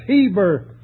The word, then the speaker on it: Eber: They've left out a little inverted colon before the E that has the equivalent of H, and the name Eber literally is pronounced Heber, from which comes the word Hebrew.